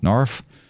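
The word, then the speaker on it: NARFE: Well, how do you pronounce it? NARF? – Yeah.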